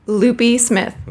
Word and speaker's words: Lupe-Smith: Lupe-Smith.